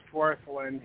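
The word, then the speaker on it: Wirthlin: Worthrin? I can't pronounce that very well. IBID, page 20. – It's just Worthlin.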